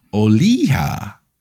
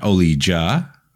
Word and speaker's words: Olija: This episode, we're going to be talking about Oliha, not Olija, as I probably pronounced that in the last episode, but it's Oliha by Skeleton Crew Studios.